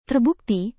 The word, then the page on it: terbukti: indonesian